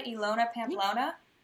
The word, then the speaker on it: Ilona Pamplona: Ilona Pamplona? – You got it perfectly.